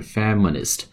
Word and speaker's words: feminist: 好，这个标题是什么呢？Four No's，就四不，feminist，feminist，f e m i n i s t，发音发的是 feminist，不是 feminist，是 feminist，是女权运动啊，女权主义啊，女权主义的运动，feminist movement，女权运动啊，这个呃惯用法大家需要记住啊，feminist movement，女权运动，哎，这什么意思呢？